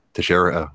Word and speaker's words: Teixeira: Is that the UFC Smith versus Texera? Well, I'm sure I can't say his name. – Teixeira. – I was closer than I thought I was going to be.